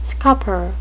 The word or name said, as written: scupper